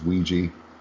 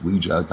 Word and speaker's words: Ouija: He says Ouija. It's Ouija, goddammit.